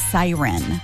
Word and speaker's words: CERN: It's apparently CERN is pronounced Siren.